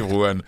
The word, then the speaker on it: Iférouane: Vous avez bien prononcé ? – Oui, bien sûr. – Iférouane, en bordure du désert de Ténéré.